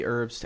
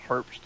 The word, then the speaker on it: Herbst: Yeah, like Riley Herbst, like terrible Herbst to gas stations in Las Vegas. Oh. They own them all. – I always say it as a silent H.